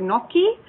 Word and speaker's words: gnocchi: and she absolutely loves the gnocchi. Am I saying this right? Gnocchi? – Gnocchi, right? – Yeah.